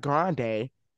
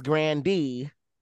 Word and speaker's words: Grande: She said, it's not Grande. It's Grandee.